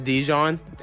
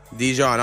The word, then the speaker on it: Dizion: And are we going to pronounce it Dijon? – That's the way. – Dijon, all right.